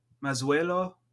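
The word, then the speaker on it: Mazuelo: Garnacha, Masuelo. – The Z is an S? – Z has an S type of pronunciation.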